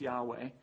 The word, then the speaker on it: Yahweh: And as you may know, when you see the Lord spelled in all caps, that's actually the personal name of God in Hebrew, probably pronounced Yahweh.